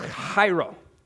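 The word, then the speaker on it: chairo (χαίρω): It's chairo. Everyone say chairo. That's right. You got to say it like you're getting ready to spit.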